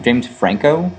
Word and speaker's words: James Franco: James Franco.